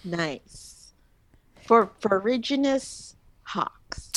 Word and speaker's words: ferruginous: It sounds correct to me because it sounds like forage, like foraging for stuff. So ferruginous hawks.